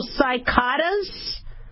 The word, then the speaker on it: cicadas: I've always called them locusts, but I guess it's C-I-C-A-D-A-S, so it's long I. So cicadas?